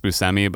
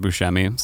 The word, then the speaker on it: Buscemi: Steve, I'm told it's pronounced Buscemi, but I always want to pronounce it Buscemi. So I'm actually going to go back to Buscemi.